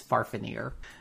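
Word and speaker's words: Fafnir: And that is how you pronounce it. I looked it up in the original dialect. It's Farfanir. Oh.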